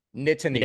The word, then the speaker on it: Nittany: Nittany. – Yeah, Nittany. – Nittany. – Yeah. – It's not, you don't pronounce the T's as much as I did, but yeah, it's Nittany.